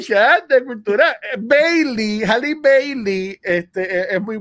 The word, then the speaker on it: Halle Bailey: Eh, Halle, Bailey.